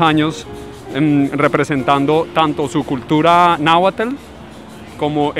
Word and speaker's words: Náhuatl: Se pronuncia la T y la L. – Sí. – Y en náhuatl es un sonido, no es una pronunciación, es tl. Y en español es tl.